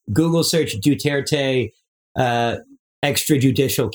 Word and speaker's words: Duterte: and it's pronounced Duterte, but I don't respect the man.